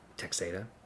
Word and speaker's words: Texada: Texada? – Yeah.